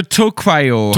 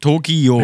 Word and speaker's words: Tokio: die dachten vielleicht so Tokyo. Tokyo, ja, genau. Oder? Genau. So. Und ja. die von haben wir in Deutschland gesagt: Pass auf, Tokio schreibt man bei uns mit dem IO hinten. Tokio. Ja.